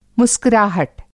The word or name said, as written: मुस्कुराहट